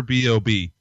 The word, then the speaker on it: B.o.B: and I hear the story about American rapper Bo B. oh, Josh, you're killing me. I don't listen to him, but I believe it's pronounced uh, rapper B.O.B. or Bob Short, but he will forevermore be known as Bo B.